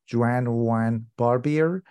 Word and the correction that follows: Joannes Barbier: Joannes Barbier, yeah.